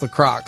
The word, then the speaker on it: LaCroix: By the way, it's actually pronounced LaCroix. So.